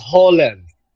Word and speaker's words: Roland: And the guy's like, no, it pronounced Holland. Holland Gracie. – It's a good bit. Yes. – And only we would find that funny because no one else knows that Brazilians pronounce their R's like an H.